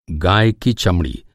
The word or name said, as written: चमड़ी